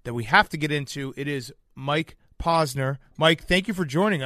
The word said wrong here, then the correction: Posner: Posner. You said it wrong. – Posner. I wrote it down, too. I actually wrote it down. Mike Posner. P-O-H-Z-N-E-R. And I still fucked it Posner.